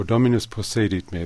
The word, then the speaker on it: Dominus possedit me: Oh, Dominus possedit me.